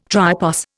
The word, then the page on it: tripos: Indian